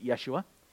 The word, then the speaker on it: Yeshua: Well, we say Jesus. We've anglicized that. They probably would have pronounced it Yeshua, which means Yahweh, God is deliverer. It's a shortened form of Yehoshua or Joshua.